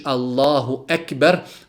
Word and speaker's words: Allahu Ekber: Čovjek kada znači izgovara riječ Allahu Ekber, mora paziti da to bude sa slovom K.